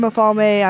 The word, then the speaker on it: Mafalme: It's Swahili, yay! So it's very hard to pronounce. And a lot of people get it wrong. I'm Mafalme.